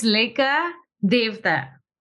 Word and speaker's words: Leka Devatha: It's Leka Devatha.